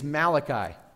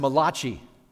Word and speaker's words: Malachi: There's a prophet there, his name is Malachi. Now, some people say that Malachi is Italian, and his name should be pronounced Malachi, which is my ultimate favorite joke to tell from the platform.